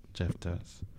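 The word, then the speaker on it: Jeftuz: It's J-E-F-T-U-Z. Jeff Does.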